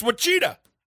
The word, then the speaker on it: Ouachita: It's Ouachita.